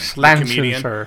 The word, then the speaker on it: Schlesinger: Schlesinger.